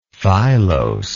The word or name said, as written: filose